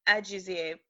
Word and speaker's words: Ajuzie: It's Ajuzie.